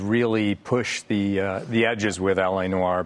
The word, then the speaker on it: L.A. Noire: But there's this L.A. Noire, okay? And I hope I'm pronouncing it right. – L.A. Noire, right.